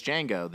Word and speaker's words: Django: And the guy goes, "Django." The D is silent, and the guy goes, "I know, because that's his name."